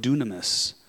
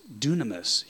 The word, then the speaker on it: dunamis: It is the word dunamis.